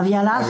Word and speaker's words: Vialas: À Vialas, oui, on dit comme ça, il faut prononcer le S.